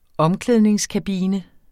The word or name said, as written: omklædningskabine